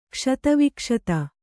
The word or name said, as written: ಕ್ಷತವಿಕ್ಷತ